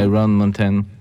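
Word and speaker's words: Iron Mountain: Ah Iron Mountain. – Voilà, vous l'avez bien prononcé euh, à l'américaine.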